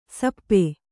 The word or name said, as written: ಶಾಪ